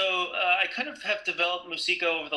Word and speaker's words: Moosiko: All right, well, here I am from Musico. Is that how you pronounce it? – Musico, yep. – Musico, yeah. – M-O-O-S-I-K-O.